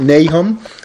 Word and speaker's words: Nahum: turn to page 184 in your workbook, and I want you to turn to the book of Nahum, or if you want to pronounce it Nahum, that's fine.